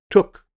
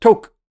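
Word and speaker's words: tuque: Last year, I thought it was pronounced took. And this year, I found out it's pronounced toke.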